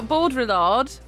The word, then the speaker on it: Baudrillard: So Baudrillard, that's the correct pronunciation.